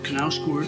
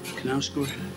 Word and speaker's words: Knausgård: Reading Carl Ove Knausgård, I think you pronounce it. Knausgård.